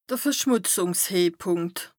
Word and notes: de Verschmùtzùngshehpùnkt: Bas Rhin

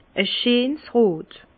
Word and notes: e scheens rot: Bas Rhin; Herrlisheim

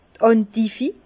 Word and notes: d' Endiv: Bas-Rhin; Herrlisheim

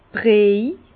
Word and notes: Briej: Bas Rhin; Herrlisheim

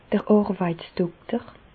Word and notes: d'r Àrweitsdokter: Bas Rhin; Reichshoffen

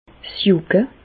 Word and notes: 's Jùcke: Alsatian; Bas-Rhin; Reichshoffen

